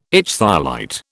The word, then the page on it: ichthyolite: British English